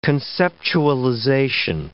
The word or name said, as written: conceptualization